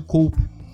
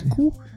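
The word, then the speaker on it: Coup: Que eu insisto que a pronúncia não é essa. – É. Como é que é isso? – É cu. – É cu. – É francês, é.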